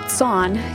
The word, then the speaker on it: Tson: Joseph Son, T-S-O-N is how that's spelled,